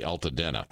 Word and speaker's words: Altadena: And I hope I'm pronouncing that section of Pacific Palisades correctly, Altadena? – Yes.